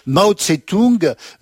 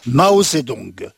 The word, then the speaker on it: Mao Zedong: Mao Tse-tung, nom qu'il convient désormais de prononcer plutôt Mao Zedong.